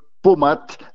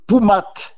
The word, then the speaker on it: poumat: Et il y a un cidre fameux du côté de Cahors, 100% lotois, qui s'appelle le pomat. Mais si on prononce bien en occitan, c'est le poumat.